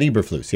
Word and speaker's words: Eberflus: Eberflus? – Matt Eberflus, yeah.